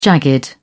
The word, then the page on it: jagged: British English